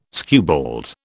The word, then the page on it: skewbald: British English